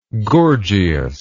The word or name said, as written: Gorgias